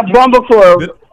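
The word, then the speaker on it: Barbancourt: I'm going to butcher this name again. Barbacore? Barbacore, the rum. – Yeah, Barbacore. – Did I butcher it? – You know what? You did good.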